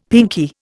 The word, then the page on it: pinkie: Indian